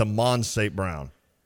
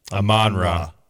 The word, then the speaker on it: Amon-Ra: Amon-Ra is how you say that? I honestly have never known that. I thought it was Amon St. Brown. – Amon-Ra. – I've never heard the Ra.